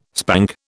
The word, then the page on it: spank: British English